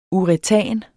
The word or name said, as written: uretan